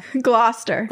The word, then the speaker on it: Gloucester: his uncle, Richard, Duke of Gloucester, how do you say it? I feel like Gloucester. But in Massachusetts, it's Gloucester.